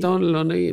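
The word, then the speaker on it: Dónal Lunny: You had Donald Lunny and... If I pronounce that correctly. – Yeah, Donal Lunny, Michal O'Donnell, and Triana, Michal's sister.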